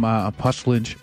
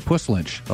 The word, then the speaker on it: Puslinch: That was Matthew Vollmer from Puslinch. Let's see if I can say it right. Puslinch, I'll say that right.